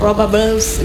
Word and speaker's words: Raw Bubbles: Oke, kita di sini ada Raw Bubble, benar nggak sih bacanya? – Betul, Raw Bubble. – Raw Bubbles, gitu nggak ya? Nggak ya? – Ada pakai S, benar. – Okay, bubbles, gitu ya.